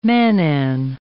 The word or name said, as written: mannan